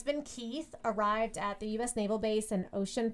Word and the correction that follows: Oceana: Virginia. – Oceana, Virginia. – Well, that's not how I would spell Oceana. – I know, but that's how it's spelled.